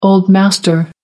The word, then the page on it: old master: American English